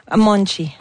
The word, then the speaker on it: Monte: Monte. – Monte, ça s'écrit M-O-N-T-E pour les intéressés.